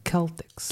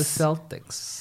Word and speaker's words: Celtics: I love correction of the Celtics because in the in the old English in old English you will pronounce the C as a K, so it becomes become the Celtics. – Celtics. – Not the Celtics.